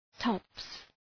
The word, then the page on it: tops: tɒps